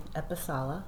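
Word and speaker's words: Episalla: Joy Epicella, and it's E-P-I-S-A-L-L-A.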